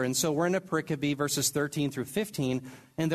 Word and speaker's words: pericope: And by the way, I'm not mispronouncing periscope. Every time I write pericope, my little corrective engine that handles misspellings says periscope.